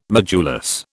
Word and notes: modulus: British English

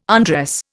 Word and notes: undress: Indian

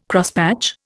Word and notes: Crosspatch: Indian

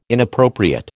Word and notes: inappropriate: American English

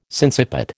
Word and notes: sinciput: American English

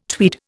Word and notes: tweed: Indian

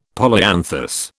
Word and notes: polyanthus: British English

